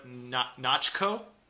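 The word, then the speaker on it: Natchios: Jennifer Garner comes in playing Elektra Nachko. – Nachos. – Or as everyone in the movie says, Elektra Nachos. – Yeah, because Nachos is her name, but nobody pronounces it with the O.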